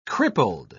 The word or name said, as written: crippled